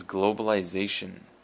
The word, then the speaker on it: globalization: And our next word is globalization. Globalization. And the emphasis is on the Z. Globalization.